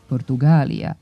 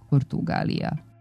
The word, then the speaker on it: Portugalija: Nedopustivo je u istoj pisanoj ili govornoj celini koristiti čas jedan, čas drugi oblik i duži izgovarati sa dugosilaznim akcentom na trećem slogu Portugalija umesto pravilnog Portugalija.